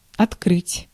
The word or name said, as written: ouvrir